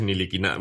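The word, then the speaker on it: Ntilikina: Entonces, la conclusión a la que hemos sacado es que el apellido Niliquina es flamenco y que por lo tanto no se pronuncia en francés y no tenemos ni idea de cómo se pronuncia en flamenco. Entonces, bueno, si lo tenemos porque la NBA ha dicho que es Niliquina, bueno, y supongo que con Niliquina.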